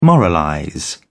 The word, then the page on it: moralize: British English